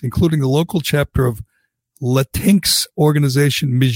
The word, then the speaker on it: Latinx: Latinx. – Latinx.